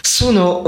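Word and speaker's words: Suno: Bon, donc, Sono. – Sono, ouais.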